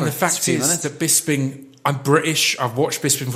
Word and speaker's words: Bisping: And then, uh, Bisping, is that how you pronounce his name? Bisping. Bisping. It was.